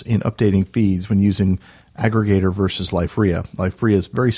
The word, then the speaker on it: Akregator: And then the last standalone client that I'll mention is the one for KDE, Aggregator. And that's spelled A-K-R-E-G-A-T-O-R. So it's almost spelled Aggregator with a K.